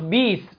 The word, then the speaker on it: بئس: وتنطق بيس، بئس تقرأ بيس.